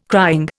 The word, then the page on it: crying: Indian